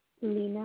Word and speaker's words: Lena: Lena Aneja. LenaAnejay. – Thank you for that information, ma'am. And can you spell that first? I mean, the first name was Lena. – Lena. – L-E. – E-N-A.